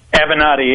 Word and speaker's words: Avenatti: Avenatti, yeah.